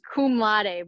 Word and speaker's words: cum laude: It's cum laude, but said it wrong, I think.